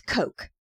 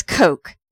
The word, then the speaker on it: Koch: Bathurst is traveling as a merchant, and his name is Coke. – He's undercover. – As Coke. – Okay. – Spelled K-O-C-H. – Yes. – But pronounced like the drink.